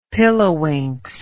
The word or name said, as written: pilliwinks